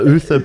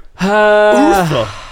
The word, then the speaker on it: Uther: The correct answer, he declared himself to be the reincarnation of King Arthur and legally changed his name to Arthur Uther Pendragon. – Uther. – Dang. – Well, I might be saying that wrong. – Arthur Uther. – U-T-H-E-R.